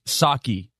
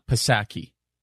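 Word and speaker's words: Psaki: Some people say, Charlie, it's Saki, not Pisaki.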